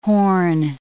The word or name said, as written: horn